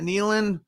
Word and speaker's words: Nealon: Nealon? – Yeah.